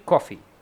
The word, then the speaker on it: Coffee: El Blas, de 25 años, ha sido acusado de asesinar a su esposa, Caroline Coffee. Se llama casi como café, pero no, no se escribe igual.